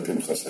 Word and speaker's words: Pinchas: Now, if Pinchas did not begin Parsha Pinchas, I mean, by the way, you will hear me say Pinchas when I pronounce it correctly in Hebrew, Pinchas, Pinchas, or Pinchas, which is not the correct pronunciation, but it's the common usage of the name among Ashkenazis, especially with the Anglos, where all names became slightly mispronounced, and the mispronunciation stuck.